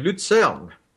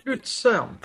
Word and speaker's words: Luzern: I don't know how to pronounce it in German, actually. Luzern. – Oh, right. – Luzern.